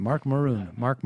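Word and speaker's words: Marc Maron: With Howl Premium, you get exclusive access to dozens of original miniseries, audio documentaries, comedy albums, all the archives from WTF with Mark Maron. How do I pronounce that? Mark Maron.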